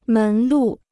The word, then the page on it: 门路: mén lù